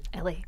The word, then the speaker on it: Elle: Evan, and I think I was saying your name wrong, is it Ellie? – Ellie. – E-L-L-E, but Ellie.